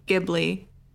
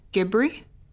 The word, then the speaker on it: Ghibli: Well, I know people in America say Ghibli. Apparently, it's Ghibri.